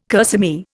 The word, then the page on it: kerseymere: Indian